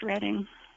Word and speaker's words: Reading: That's the way it's spelled. So it would be the natural pronunciation, but it's actually pronounced Reading.